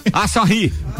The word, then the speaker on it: Asahi: Asahi.